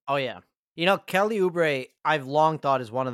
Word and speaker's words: Oubre: I would put number three as like Jalen Green or like Kelly Oubre. Is that how you say it? – Oh yeah, Kelly Oubre.